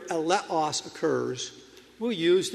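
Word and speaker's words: eleos (ἔλεος): The Greek word that is here translated mercy in Matthew 23:23 is pronounced eleos.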